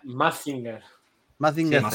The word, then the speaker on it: Mazinger: Uno de los que hizo eh, estos diseños de luchadores y boxeadores es el mismo estudio que hizo Magic, eh, a ver si lo digo bien, magic Z, los del robot. – Ah, el Magin Z, Z. – Mazinger.